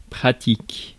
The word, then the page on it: convenient: American English; [kənˈviː.nɪənt]